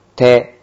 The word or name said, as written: te